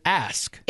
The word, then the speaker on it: ask: Is it AXE? No, AXE. – Well, that's how you do it. The way it's spelled here is AKS. So is it AX or is it ASK? – It's ASK.